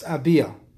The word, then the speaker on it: Abia: They are written acrostically as Abia, pronounced Abia, like I just said, Abia.